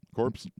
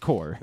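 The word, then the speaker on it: corps: Corpse? – It's not corpse. It's definitely just core. – Yeah, so be it. – Not that hard. – I'm being honest. – It's spelled like corpse, but it's core.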